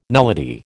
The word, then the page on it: nullity: American English